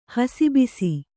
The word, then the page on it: ಹಸಿಬಸಿ: hasi basi